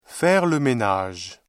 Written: faire le ménage

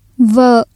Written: vợ

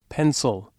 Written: pencil

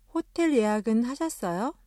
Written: 호텔

